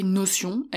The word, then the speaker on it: Notion: Alors, L'outil Notion, alors ça s'écrit Notion, N-O-T-I-O-N, bon je le dis à l'anglaise quoi, mais l'outil Notion, euh, c'est un outil qui peut parfois être compliqué à prendre en main dès le départ.